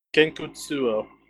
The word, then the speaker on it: Gankutsuou: Am I saying that okay? Gankutsuou? How do you say it? – That would be, let's see, I know. Gankutsuou.